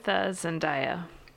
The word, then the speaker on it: Zendaya: All right, so I went with Zendaya.